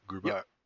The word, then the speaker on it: Grubauer: I just is Grubauer, is that how you say his name? Grubauer.